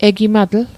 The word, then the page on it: egimatl: Mi'gmaq